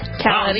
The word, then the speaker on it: Khaled: Khaled.